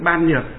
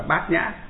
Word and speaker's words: bát nhã: Nhưng mà nhà Phật chúng ta thì đọc trại âm đi, chữ ban nhược đọc là bát nhã.